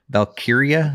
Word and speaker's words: Valkyria: Well, I wanted to call it Valkyrie, but that's not what I was reading. So it's not how it's spelled. It's Valkyria. Elysium.